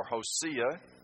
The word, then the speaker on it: Hosea: And Amos prophesied contemporary with the prophets Hosea, or Hosea, as some pronounce it, and also in the early part of the reign of Uzziah, right before Isaiah picks in, the major prophet Isaiah.